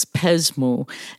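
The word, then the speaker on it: Pesmou: Pretty active on Twitter, and my handle is a Greek word, but it's spelled P like Peter, E S like Sam, M O U, and it's Pesmu, and it means tell me.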